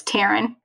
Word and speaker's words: Taryn: Yes, and it rhymes with your name. It's Taryn, Karen, but no worries.